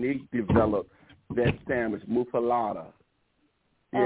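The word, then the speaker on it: muffuletta: Well, before I forget, how is this sandwich pronounced? Is it muffaletta or muffaletta? – Muffaletta.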